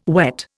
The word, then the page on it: wet: Indian